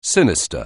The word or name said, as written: sinister